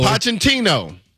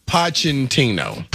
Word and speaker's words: Pochettino: Mauricio Pochettino. – Pochettino? – Pochettino.